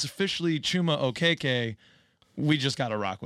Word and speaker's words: Okeke: Okeke.